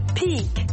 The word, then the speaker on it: pique: Pique, to pique. Does this topic pique your interest? Ce sujet vous intéresse-t-il? Tournons-nous vers un autre mot prononcé de la même manière, le mot peak, sommet.